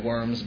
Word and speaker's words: Worms: spelled like worms, but Worms, German, W is a V, or an F, depending on whatever.